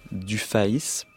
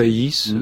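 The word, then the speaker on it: Dufaÿs: Jacques Hoppic, Charles Baudelaire et Caroline Archambault Dufaïs. C'est comme ça qu'il faut le prononcer. – Dufaïs, oui, oui.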